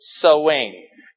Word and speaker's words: Samhain: All right, it's not spelled Samhain; it's spelled S-A-M-H-A-I-N, but it's pronounced Samhain.